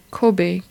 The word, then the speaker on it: Kobe: At that time it was called Kobe, K-O-B-E, or that's how they pronounce it.